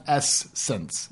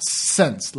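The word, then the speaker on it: SSENSE: That's S-S-E-N-S-E. – And we think it's pronounced Essence. – Yeah, Essence. – Not Sense, like I thought it was originally.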